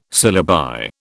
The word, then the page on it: syllabi: British English